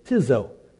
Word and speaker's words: ktizo: And the word that Jesus used was, and the Greek word is tizo, K-T-I-Z-O. The K is silent. Tizo.